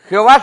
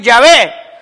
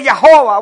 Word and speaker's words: Yahvé: Sería, alguien me, no, me, mira, no se dice eh, Jehová, se dice Yahvé o se dice Yahová. Bueno, como usted le quiera decir, si va a hablar en hebreo va a decir Yahvé.